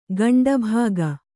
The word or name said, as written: ಗಂಡಭಾಗ